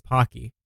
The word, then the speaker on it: Pocky: So mission accomplished, but among the comments, people are like, you pronounced it wrong. It's Pokey. And I was like, it's literally not. It's Pocky. And they were like, I'm Japanese and it's Pokey.